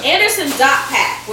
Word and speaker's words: Anderson .Paak: The dot is pronounced. He said he took so much time as being the underdog, nobody paying attention to him. He wants you to pronounce the dot. So it is Anderson Dot Pack.